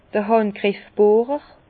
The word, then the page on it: d'r Hàndgriffbohrer: Bas-Rhin; Herrlisheim